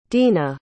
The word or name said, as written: dena